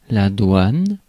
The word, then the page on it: douane: France; [dwan]; /dwɛn/